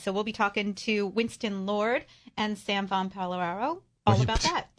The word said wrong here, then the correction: Pallaro: You mean Pallaro? – Pallaro. Did I say it wrong? – That's easy for you to say.